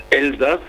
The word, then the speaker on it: Elsass: Well, it's Elsass if they say it in German. It's Alsace if you say it in French.